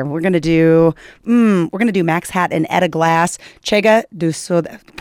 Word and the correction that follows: Chega de Saudade: Pronounce it correctly for me. Get over there. – Chega de Saudade. – Thank you so much.